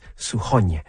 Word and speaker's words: Suchoň: It's S-U-C-H-O-N, and the N has a hatchet on it, which is why it's Suchoň.